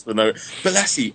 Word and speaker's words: Bolasie: Is that how you say his name, Bolasie? – Yeah. That's him.